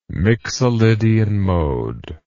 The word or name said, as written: mixolydian mode